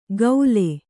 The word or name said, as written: ಕೌಲೆ